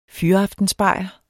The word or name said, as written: fyraftensbajer